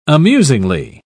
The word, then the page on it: amusingly: British English